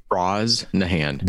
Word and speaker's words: Brosnahan: Brosnahan.